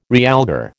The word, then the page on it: realgar: American English